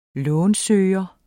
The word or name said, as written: lånsøger